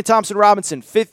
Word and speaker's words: Thompson-Robinson: Again, Dorian Thompson-Robinson. Okay, I did say it right. I was going to say Robbins.